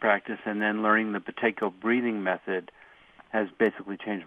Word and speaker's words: Buteyko: Now, you pronounce that Buteyko. – Yeah, like in the Halloween Buteyko. It's B-U-T-E-Y-K-O.